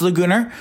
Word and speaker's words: Laguna: I mean, Morris Laguna Beach, it's pronounced Laguna.